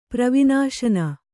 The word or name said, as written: ಪ್ರವಿನಾಶನ